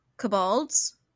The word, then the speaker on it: kobolds: There's also Cabaldo, kobolds. There's also kobolds. Kobolds? – How do you spell it? – K-O-B-O-L-D-S.